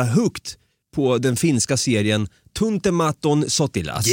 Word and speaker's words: Tuntematon Sotilas: Och du blev, du blev lite impad över att jag kunde Tuntematon Sotilas. Det blev jag, eh, för du uttalade väldigt bra.